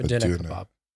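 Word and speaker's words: döner kebab: A doner kebab. – Doner kebab. – Yeah. – All right. For those listening and you happen to be in the area or you can find one, doner kebabs.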